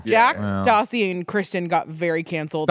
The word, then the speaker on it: Stassi: Stassi. Not Stacy? – No. – It's like Natasha. – Oh, Stassi.